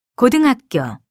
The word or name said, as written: 고등학교